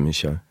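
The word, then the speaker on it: Michôd: So when you work with somebody like Michaud, is that how you say his name? – Yeah, David Michaud.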